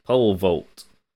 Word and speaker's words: pole vault: Rich Eisen doing the poll vote, poll poll vote, poll vote. Why can't I say vote? pole vault. Pole vault? You're saying it right.